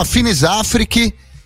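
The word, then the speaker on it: Finis Africae: É, se escreve Africai, mas é, uma, é, um, é, uma, é um termo latim, se chama Finis Afrique, e quer dizer em latim ali nos limites da África, no fim da África, né, na, na fronteira da África.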